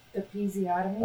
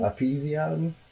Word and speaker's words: episiotomy: So how do you pronounce that again? – Episiotomy. – Episiotomy. There you go.